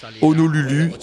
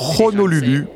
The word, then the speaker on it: Honolulu: Alors, ça serait Honolulu si on le prononçait en la française, mais vu que c'était espagnol et que le H se prononce en Espagne, c'est Honolulu embarqué.